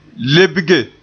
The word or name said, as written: lebge